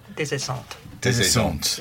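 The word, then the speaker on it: Des Esseintes: It's about a character called Desessent. That's right, isn't it? How's my pronunciation? – Desessant. – Yeah. Thank you. Not Des, as in desert.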